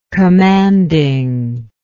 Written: commandingness

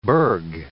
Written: burg

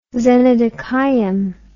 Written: xenodochium

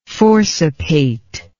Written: forcipate

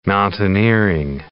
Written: mountaineering

